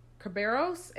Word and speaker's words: Kerberos: Kerberos and Styx.